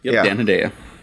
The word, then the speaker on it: Hedaya: Hedaya. – Dan Hedaya.